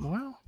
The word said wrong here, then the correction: Moe: It's Moe, but it's all Moe, good, y'all.